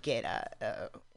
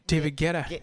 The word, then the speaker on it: Guetta: Just a whole bunch of DJs, but really famous, you know, like Martin Garrix and David Guetta. You know, is that how you pronounce it? – David Guetta. – Guetta. – Yeah. – Guetta.